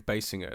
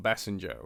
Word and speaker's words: Basinger: By the way, just in pronunciation, it is Kim Basinger. It's not Basinger or something like that. – I think it's Basinger. Write to us. – write to us. Corrections and clarifications.